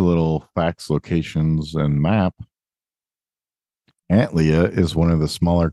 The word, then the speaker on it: Antlia: The constellation name Antlia is pronounced Antlia, motherfucker.